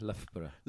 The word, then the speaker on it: Loughborough: How would you say that? – Loughborough. – Lough, with an F.